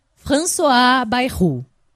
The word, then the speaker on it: François Bayrou: François Bayrou.